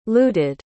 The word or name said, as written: looted